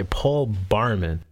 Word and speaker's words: Paul Barman: Am I saying it right? Paul Barkman? Barman? Okay, Paul Barman.